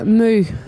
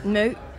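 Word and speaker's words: Mø: Now, I think I'm saying that right. Some people say Mo, some say Moo. Actually, let's just hear from her how to pronounce it. – You would say Moo. – Moo? – Moo. – Okay. – Oh, you almost got it. – I'll practice. It's the O with the slash through it, right? – Yeah.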